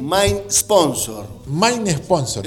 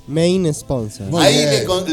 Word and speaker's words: main sponsor: No, en realidad se dice Main. – Claro, muy bien. Ahí está, bien. Ahí está, díganos algo, ¿cómo se dice? – Main Sponsor.